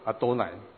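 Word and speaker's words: 阿 多 乃 (אֲדֹנָי): 那么这几个原点就告诉他们怎么样去念，加上这几个原点和短线以后，他念作阿多乃。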